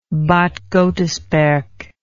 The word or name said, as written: bad godesberg